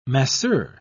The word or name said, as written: masseur